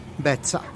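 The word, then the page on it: bet'za: Teotitlán del Valle Zapotec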